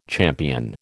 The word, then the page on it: champion: American English